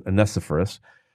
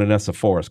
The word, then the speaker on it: Onesiphorus: I had been reading that passage so flippantly apart from Philemon that I thought for a long time that Onesiphorus, or as some people pronounce it, Onesiphorus, but I'm going Onesiphorus because I have to differentiate between Onesimus and Onesiphorus.